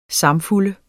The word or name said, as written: samfulde